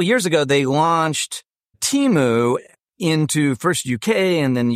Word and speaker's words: Temu: So I always pronounce Temu as in team.